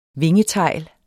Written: vingetegl

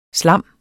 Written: SLAMMETS

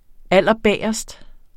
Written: allerbagest

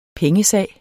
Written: pengesag

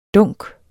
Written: dunk

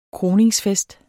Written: kroningsfest

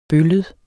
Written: bøllet

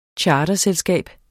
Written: charterselskab

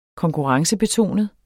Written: Konkurrencebetonet

